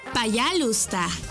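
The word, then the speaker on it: Payalusta (пожалуйста): payalusta. – ¿Cómo? – Payalusta. Una vez más. Payalusta. Y se escribe... payalusta. P-A-Y-A-L-U-S-T-A.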